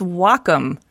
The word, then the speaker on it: Wacom: No, it's Wacom. – Oh. – Pronounced wa, like the Japanese word for harmony, and com for computer.